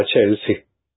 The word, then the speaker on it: Chelsea: Se escribe Chelsea. Pero se pronuncia Chelsea.